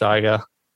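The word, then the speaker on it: Shyga: It's called, I don't know if I pronounced that all right, but I'm hoping it's pronounced Shiger. – Yeah, that's it.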